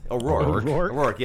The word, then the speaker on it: O'Rourke: That guy, what's that, how do you say his last name again? O'Rourke, yeah, sorry. O'Rourke. That's terrible. Sorry about that.